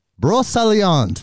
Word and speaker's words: Brocéliande: Brocéliande.